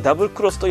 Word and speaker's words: XX: 私が最後にやったモンハンは 3DS のモンハンダブルクロスで、公式では XX と書いてダブルクロスと読ませていましたが、私は当時放送していたテレビアニメアニトレ、ペケペケ一つ屋根の下でのペケペケの読み方をオマージュしてモンハンペケペケと一人呼び続けておりました。